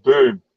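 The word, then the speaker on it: Böhm: Did I pronounce that right? Boom or Boom? How do you say it? – Mandy Boom. – Mandy Boom. It's like that.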